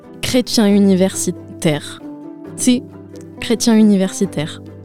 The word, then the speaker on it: chrétien universitaire: Inténtalo, chrétien. – Chrétien. – Universitaire. – Chrétien université. – Muy bien.